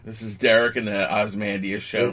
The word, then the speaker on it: Ozymandias: Like, the only, other than Manhattan, the only one who was actually supposed to be even slightly super was Ozymandias, or however you pronounce it. Ozymandias? – Yeah, Ozymandias.